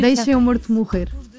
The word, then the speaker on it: Deixe-me Morto Morrer: It's in Portuguese, it's very hard to pronounce Portuguese, but it's almost perfect. – No, you Deixe-me Morto Morrer.